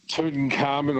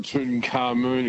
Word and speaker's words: Tutankhamun: Tutankhamun or Tutankhamun, you can pronounce either way.